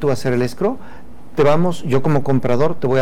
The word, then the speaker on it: escrow: se escribe E-S-C-R-O-W, escrow. Escrow.